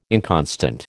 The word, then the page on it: inconstant: American English